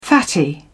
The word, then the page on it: fatty: British English